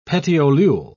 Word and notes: petiolule: British English

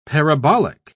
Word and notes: parabolic: British English

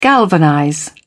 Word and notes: galvanize: British English

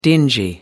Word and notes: dingy: American English

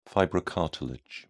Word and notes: fibrocartilage: British English

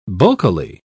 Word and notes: bulkily: British English

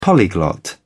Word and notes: polyglot: British English

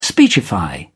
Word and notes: speechify: British English

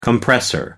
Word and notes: compressor: American English

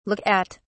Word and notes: look at: British English

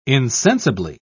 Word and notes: insensibly: British English

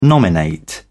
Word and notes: nominate: British English

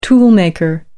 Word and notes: toolmaker: American English

